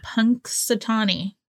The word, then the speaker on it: Punxsutawney: I remember teachers talking about it in school, and we would always like wait to hear if Punxsutawney Phil, there's an N there. – What? – Look at it. There's an N. – There's an N. – Punxsutawney. – I have cast so much shade on people for pronouncing that N because I didn't think there was an N there. – I know. And then look when I started doing the research, I was like, there is an N.